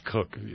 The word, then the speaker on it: Coke: It's like in England, people whose names are C-O-K-E and they're pronounced Cook.